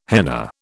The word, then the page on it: henna: British English